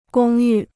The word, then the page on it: 公寓: gōng yù